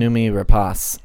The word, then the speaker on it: Noomi Rapace: Noomi Rapace.